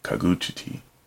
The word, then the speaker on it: Kagutsuchi: So we move on to, I think I've always said Kagutsuchi, but that's the American Kagutsuchi. That's the American way of pronouncing it. It's probably Kagutsuchi, if I had to guess. From old Japanese, Kagutuchi, pardon, Kagutsuchi, also known as Hi no Kagutsuchi or Homusubi, among other names, is the god of fire in classical Japanese mythology.